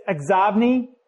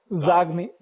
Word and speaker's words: Xobni: I saw somebody has I don't know how to say this, Xobni. X-O-B-N-I. It's an add-in for Outlook. Zogmi.